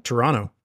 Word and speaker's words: Toronto: You don't pronounce the second T. – What is it? Toronto? – Toronto. – Ah.